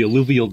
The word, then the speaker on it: alluvial: Is it alluvial? – Alluvial, yes.